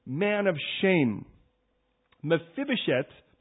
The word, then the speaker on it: Mephibosheth: Try that, Mephibosheth. Come on now, this is Hebrew, you cannot say it like that. Mephibosheth.